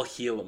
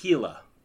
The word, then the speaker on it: Gila: It's all Gila monster. So. – Is it not pronounced like that? – It's pronounced Gila.